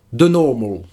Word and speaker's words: The Normal: En attendant, eh bien, on écoute The Normal.